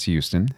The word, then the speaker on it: Houston: And that's when you know you're from Texas, when you barely pronounce the H, it's Houston.